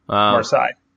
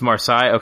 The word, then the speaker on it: Marseille: Five Fingers for I don't know how to say it, pronounce the name. I guess it's French. – Marseille. – It's Marseille. Okay, that's why.